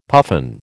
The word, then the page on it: puffin: American English